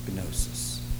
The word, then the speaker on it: gnosis: It comes from the Greek word gnosis. You don't really pronounce the G. Gnosis, we'll say.